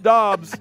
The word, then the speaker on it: Doubs: Romeo Doubs, spelt like Dubes, pronounced Doubs.